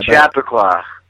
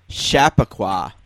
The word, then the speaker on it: Chappaqua: Man, you asked about the name and then you blew the hometown. – Did I blow it? Close. I had it phonetically written down. – Chappaqua. – Chappaqua.